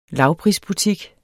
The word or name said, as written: lavprisbutik